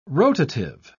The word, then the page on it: rotative: British English